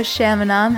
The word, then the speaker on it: Shamanom: Hello, Shamanom.